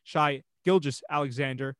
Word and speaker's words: Shai Gilgeous-Alexander: I always mispronounce that guy's name and I got to stop doing that. Shai Gilgeous-Alexander. I think he has another big year this year.